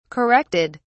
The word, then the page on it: corrected: British English